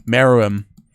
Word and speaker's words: Meruem: Miriam. God, I can never say his name. The bad guy. Meruem got added.